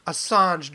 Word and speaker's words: Assange: Assange. Julian Assange to a terrorist and saying, you know, he's just as bad as Bin Laden.